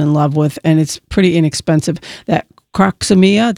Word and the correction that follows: Crocosmia: did I say that right? Croxomia? – Crocosmia, okay.